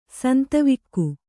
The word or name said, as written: ಸಂತವಿಕ್ಕು